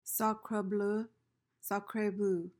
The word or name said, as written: sacre bleu